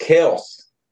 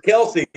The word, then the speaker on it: Kelce: Uh, the Kelces, because you know, it's Travis Kelce and then Jason, J- Jason Kelce played, you know, their actual name is pronounced Kels.